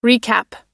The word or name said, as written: recap